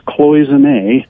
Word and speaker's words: Cloisonné: Oh, I know the song "Cloisonné," which is pronounced "Cloisonné" mm-hmm. in the song, has John Flansburg playing the role of the raindrop that he's singing.